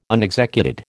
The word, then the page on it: unexecuted: American English